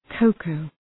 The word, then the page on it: cocoa: ‘kəʋkəʋ